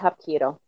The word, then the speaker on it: Hapkido: How do you pronounce the type of martial arts that you practice? – It's Hapkido. – Okay. – Yeah. – Okay. – Hapkido.